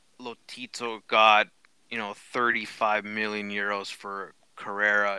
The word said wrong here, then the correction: Correa: is absolutely Correa. – There you go. I pronounce it wrong. Correa.